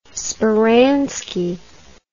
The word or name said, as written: speranski